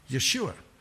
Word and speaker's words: Yeshua: Some people pronounce the name of Jesus in Hebrew that way, Yeshua.